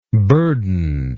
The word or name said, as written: burdened